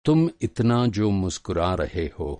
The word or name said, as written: मुस्कुराना